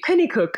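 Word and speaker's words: Penicuik: Everybody struggles, don't worry, it's Pennyquick. – Pennyquick, okay, cool.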